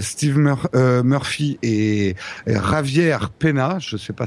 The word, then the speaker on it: Peña: et, le N avec une virgule dessus, ça se dit comment Peña. – Peña. Javier Pena.